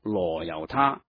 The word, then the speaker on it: 那由他: 那由他，传统读作罗由他，但是梵文是 Nayutta，所以应该读成那由他更为贴切的。